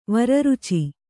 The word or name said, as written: ವರರುಚಿ